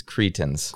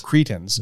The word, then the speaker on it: Cretans: And now you've confused us by calling the Cretans the Cretians. – Right. – And so are you on the record? – Yep. – What's the official pronunciation? – It's Cretans. – Cretans, yep.